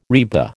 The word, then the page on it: RIBA: American English